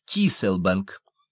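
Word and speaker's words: Cay Sal Bank: Здесь воды Гольфстрима огибают самую южную точку Соединенных Штатов, и тут же, подобно островку безопасности, на пересечении двух магистралей лежит треугольник Кейселбанк, который произносится как Киселбанк.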